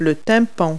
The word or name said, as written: eardrum